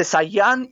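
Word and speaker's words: Zanjan: Se dice Sayan y se escribe Sanjan.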